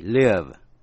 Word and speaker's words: live: Chữ live, động từ L-I-V-E, live, có nghĩa là sống. Và live, L-I-V-E, là tĩnh tự, có nghĩa là sống động.